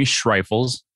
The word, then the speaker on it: Schreifels: Jeremy Schreifels. It's very German.